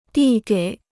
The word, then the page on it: 递给: dì gěi